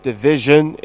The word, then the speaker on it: division: Division is jin, S-I-O-N words. When there is a vowel preceding the S goes j, takes that jin.